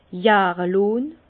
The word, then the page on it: jahre lonn: Bas-Rhin; Herrlisheim